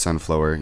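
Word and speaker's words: Sunflo'er: I'm pretty sure it's Sunflower. Yes, the spelling of that is S-U-N-F-L-O apostrophe E-R.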